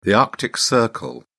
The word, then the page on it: arctic circle: British English